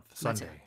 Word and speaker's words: sundae: I've seen sundae spelled like this. – 아 그래요? – Yeah. – Sundae는 아이스크림이에요. – Yeah, so it's S-U-N-D-A-E. But in English, you would read that as just like the Sunday.